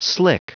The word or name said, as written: slick